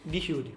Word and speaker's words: Djibouti: pero es Djibouti. – Digibuti. Ah, bien. La D se pronuncia, no es muda.